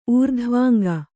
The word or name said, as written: ಊರ್ಧ್ವಾಂಗ